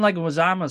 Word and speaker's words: Leguizamo: He was supposed to Leguizamo.